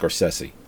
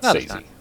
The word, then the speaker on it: Scorsese: But it is pronounced Scorsese, not Sazy.